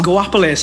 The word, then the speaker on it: Guapale: For years, I used to call her Joapel. – Guapale sounds much better now that I know it.